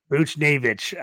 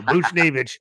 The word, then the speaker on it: Buchnevich: Buchnevich. – I always butcher his name, and I always got to rely on Toast to save me. So Buchnevich, left winger.